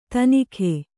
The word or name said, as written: ತನಿಖೆ